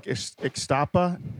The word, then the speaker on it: Ixtapa: Brad, you were saying that you like Ixtapa. Is that how you say it? – Yes. Yeah, up north.